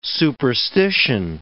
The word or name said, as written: superstition